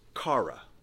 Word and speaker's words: chara: But joy here in the Greek would sound something like chara.